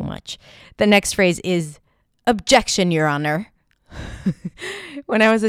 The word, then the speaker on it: objection: So the reason why I want to talk about the word objection, because it has a fun little schwa at the beginning, even though it's spelled with an O.